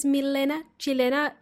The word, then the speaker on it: Milena Chilena: My name is Milena Chilena, even though I'm not a Chilena, but I call myself that because it rhymes.